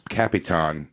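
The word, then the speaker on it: capitán: So you said capitan. – Yeah, capitan. El capitan. Yeah. – You're saying it right.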